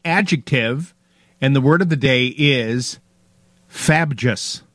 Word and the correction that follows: frabjous: Nope. Frabjous. – Spell it. – Well, yeah. Use it in a sentence. F-R-A-B-J-O-U-S. Frabjous.